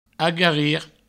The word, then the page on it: agarrir: agarri